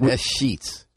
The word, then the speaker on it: escheats: I love that word, E-S-C-H-E-A-T-S. Yeah. Do I say it right, or is it S-sheets? – Yeah, that's right.